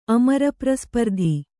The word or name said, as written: ಅಮರಪ್ರಸ್ಪರ್ಧಿ